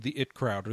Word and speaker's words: The IT Crowd: So, many of you are familiar with the British sitcom The It Crowd, or The IT Crowd. There's actually no official pronunciation for the name of the show. It's supposed to be pronounced both,